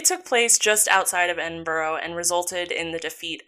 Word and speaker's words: Edinburgh: Um, it's spelled weird, but I, it is Edinburgh.